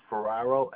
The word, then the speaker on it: Ferraro: Yep, Vincent, Vincent, and the last name is Ferraro, F as in Frank, Erraro.